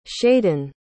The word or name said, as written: shaiden